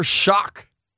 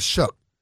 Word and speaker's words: Shough: Shock or Shuck? – I don't know. – Shuck. – S-H-O-U-G-H. How would you say that? – S-H-O. I probably would say Shock. – Show. Shuck. But they call it, I think it's Shuck.